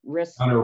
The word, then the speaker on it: remonstrant: and then the young counter restaurant, I can't pronounce that word, remonstrant. Yes. – Remonstrant, thank you.